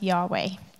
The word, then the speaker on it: Yahweh: And scholars have made the educated guess that we pronounce this name as Yahweh. But the vowels in the word are, in fact, just a guess, because only the consonants were written down.